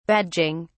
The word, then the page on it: badging: British English